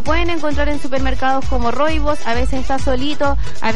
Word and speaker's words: rooibos: Es un arbusto que se llama rooibos. Se escribe R-O-O-I-B de burro, O-S, rooibos.